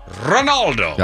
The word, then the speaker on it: Ronaldo: It's Ronaldo.